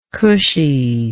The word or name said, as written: cushy